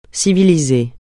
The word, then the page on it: civiliser: francais